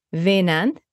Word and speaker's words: Wynand: It's WYNAND, pronounced Veinand, and the surname is Jacobs.